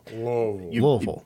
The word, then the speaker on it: Louisville: do not say Louisville or anything remotely close to that. Kind of have to get lazy with the pronunciation. Mm-hmm. Louisville. – Louisville. – Like you have marbles in your mouth.